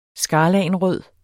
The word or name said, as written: skarlagenrød